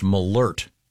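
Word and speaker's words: Malört: And this says it's pronounced malert. – Malert. – There's a, an umlaut, the two dots over the O? This has a pronouncer saying malert.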